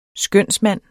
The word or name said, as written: skønsmand